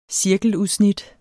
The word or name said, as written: cirkeludsnit